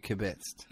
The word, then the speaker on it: kibitzed: And then, we kibitzed a bit about skateboarding and another guy came in and I was talking about you put the emphasis on the second syllable. You kibitzed.